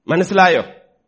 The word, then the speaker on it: Manasilayo: Manasilayo. That is the actual pronunciation.